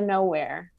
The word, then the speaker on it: Knowhere: It's called Door to Knowhere. – Door to Knowhere. – And it's spelled K-N-O-W and then here. So like no here.